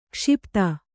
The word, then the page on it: ಕ್ಷಿಪ್ತ: kannada